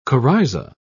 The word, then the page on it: coryza: British English